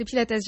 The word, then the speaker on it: Pilates: C'est Pilates, Joseph Pilates avait des origines grecques, donc et ça se prononce, et il était allemand surtout, donc on prononce toutes les lettres en allemand. Et donc je pense que lui-même disait Pilates. Et puis la méthode s'est développée aux États-Unis. Donc aux États-Unis aussi, on prononce toutes les lettres. Mais c'est en France qu'on a un peu enlevé le S. Donc on peut dire les deux.